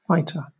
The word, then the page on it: fighter: British English